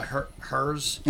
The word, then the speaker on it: Hirs: And then the new, the other one is The Hers Collective, We're Still Here. – Spell that? – H. It's The Hers. – Like the chips? – H-I-R-S. – H-I-R-S. Collective? – Yes.